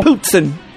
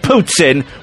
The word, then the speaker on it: poutine: I pronounced it poutsin, which I still contend is a proper way of saying it.